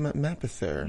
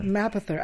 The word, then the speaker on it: Mapother: William Mapother. – Mapother. I was like, how did I say this name? – Yes. – Mapother.